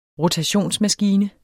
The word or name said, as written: rotationsmaskine